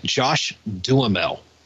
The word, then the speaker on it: Josh Duhamel: But I'm going to pronounce it Josh Duhamel.